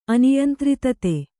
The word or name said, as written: ಅನಿಯಂತ್ರಿತತೆ